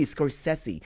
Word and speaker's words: Scorsese: And it is really Scorsese.